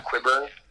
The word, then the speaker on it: Qyburn: When Qyburn looked at, am I pronouncing that right? – Yeah, Qyburn.